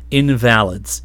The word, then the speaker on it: invalids: I believe they pronounced it invalids in the movie.